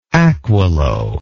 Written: aquilo

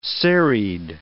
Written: serried

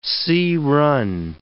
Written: sea-run

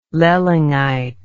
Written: lollingite

